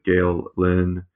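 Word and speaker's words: Gael Linn: Gael Lynn. I'm sure I'm mispronouncing that. I apologize. – No, you're not. Not this. That's perfectly pronounced.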